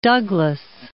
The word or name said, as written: Douglass